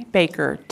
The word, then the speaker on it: Baker: Yes, Tracy Baker, T-R-A-C-Y-B-A-K-E-R.